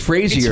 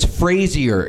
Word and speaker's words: Fraser: So, no, it's pronounced Frazier. – It's Fraser. – Frazier. – That's like a pet peeve of his. I've seen interviews where he's like, it's not Fraser, it's Frazier.